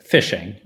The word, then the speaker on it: phishing: Because it phonetically sounds like I'm going to have a really good time out on the pond. But spelled P-H-I-S-H-I-N-G.